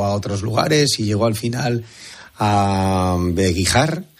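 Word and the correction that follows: Begíjar: Perdona, Bejíjar. – Bejíjar, o sea, es las dos con J, ¿no? – Complicado. Bejíjar, GJ, pero se pronuncia Bejíjar, vale.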